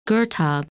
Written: Girtab

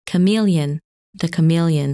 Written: Chamaeleon

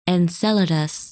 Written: Enceladus

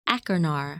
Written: Achernar

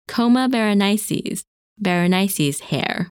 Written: Coma Berenices

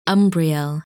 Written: Umbriel

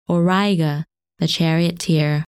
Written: Auriga

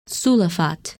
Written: Sulafat

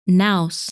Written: Naos

